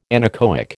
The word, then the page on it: anechoic: American English